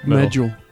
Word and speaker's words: Medjool: How do you say that word? – Yeah, I think you're right. Medjool.